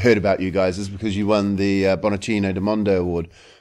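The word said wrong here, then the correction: Bollicine del Mondo: Is that how you pronounce it? – Bollicini del Mondo, which Bollicini is sparkling of the world, I think it means in Italian.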